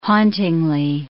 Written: hauntingly

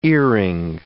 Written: earring

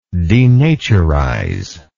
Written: denaturize

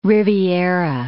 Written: riviera

